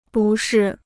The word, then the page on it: 不适: bù shì